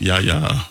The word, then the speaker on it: Yahya: I'm about to mess his name all the way up. Yahweh Abdullah Mateen? – No, it's Yahya. I forgot how to pronounce it, but yeah, you're close.